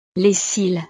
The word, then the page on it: les cils: frances